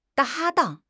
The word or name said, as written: 搭档